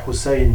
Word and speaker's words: Husayn: donc on dirait Hussein en, arabe, la prononciation tunisienne ça serait Hassin, mais c'est pas très grave.